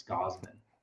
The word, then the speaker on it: Gausman: I keep on saying Gausman, but it's Gausman, right? – Yeah. – Gausman. I just like really pronouncing that U.